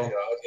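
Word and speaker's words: Ødegaard: We're talking about we've got to talk about the new signing, whatever his name is, Odegaard. Is it pronounced like that? I'm not sure. – Odegaard, yeah.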